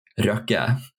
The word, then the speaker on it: Røkke: And then other than that, like the rest of the name is actually not that hard, except that you guys don't know how to roll your R's. So because his last name is Røkke.